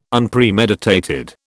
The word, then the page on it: Unpremeditated: British English